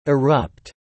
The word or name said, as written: erupt